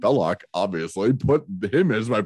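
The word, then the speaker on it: Belloq: Belosh. Belloc. It's pronounced Belloc.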